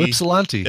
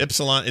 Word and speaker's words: Ypsilanti: We got one from Adam from Ysplante, Michigan, or how do you say it? YP. – Oh, Ypsilanti. – Ypsilanti. Is that how you say it? – Okay.